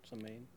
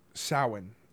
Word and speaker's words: Samhain: Samhain. – Samhain. – That's how it's pronounced for some reason. It's not how it's spelled, but whatever.